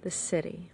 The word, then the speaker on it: Siddhi: And then there is the full expression of this gene key called the city. And that's spelled S-I-D-D-H-I.